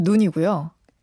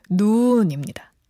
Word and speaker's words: 눈: 사람 눈은 눈이고요. 내리는 눈은 눈입니다. – 오. 단음, 장음. 눈.